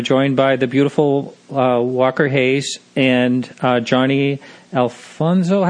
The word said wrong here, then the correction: Alonso: How do you say his last name? – Johnny Alonso, yeah.